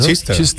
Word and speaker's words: Chichester: Eso sería, se pronunciará Chichester o algo así, ¿no? – Chichester, claro.